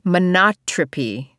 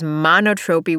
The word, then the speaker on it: monotropy: It is monotropy. Is that right? I think that's right. I had been thinking of it as monotropy, which I knew was horrible.